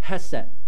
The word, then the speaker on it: hesed: That word is called hesed, like H-E-S-E-D. Sometimes it pronounces as K, but it's H-E-S-E-D.